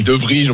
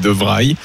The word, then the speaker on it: De Vrij: Moi, j'ai dit De Vrij. On me dit oh, n'importe quoi, c'est De Vrij.